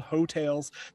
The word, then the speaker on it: Heaux: As you said, it's called Hotels. That's spelled H-E-A-U-X.